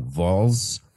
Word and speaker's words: Vols: I think I'm saying that wrong. Vols. V-O-L-S.